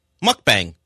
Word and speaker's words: mukbang: Mukbang.